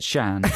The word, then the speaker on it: Sian: Is that S-I-A-N? – Yeah. I was saying it wrong. – Shan. – I say it Shan.